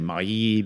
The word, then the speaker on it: Mailly: The last name is spelled M-A-I-L-L-Y. So I'm saying Mailly because it just seems very French to me. – It's Mailly.